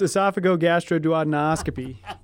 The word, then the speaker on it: esophagogastroduodenoscopy: Yeah, it's esophagogastroduodenoscopy, EGDs.